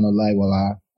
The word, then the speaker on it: Olayiwola: And interestingly, Osoba is my surname, but it's easier to pronounce, right, than Olayiwola.